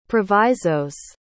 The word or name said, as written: provisos